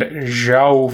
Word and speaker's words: João: She gets the clue thanks to a 1386 treaty signed by Richard II and João I. England's oldest ally is this country. And she gets it correct with what is Portugal. I know it's pronounced kind of like the name Joe, but I can't.